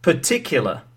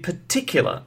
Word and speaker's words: particular: Where the hell did I place the stress in the word particular? Right? Did you notice that? In particular. So, of course, I wrongly placed the stress. It has to go on the second syllable.